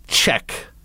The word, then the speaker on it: Czech: She is a Czech. She is a Czech, which I really want to pronounce that Z every time I see it.